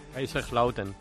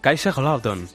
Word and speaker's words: Kaiserslautern: El Kaiserslautern, ¿cómo se dice, espera, cómo se dice? – Kaiserslautern. – Kaiserslautern. – Sí, casi como en español.